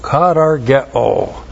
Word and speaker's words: katargeo: K-A-T-A-R-G-E long O. Katargeo.